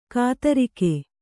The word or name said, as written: ಕಾತರಿಕೆ